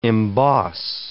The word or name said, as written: emboss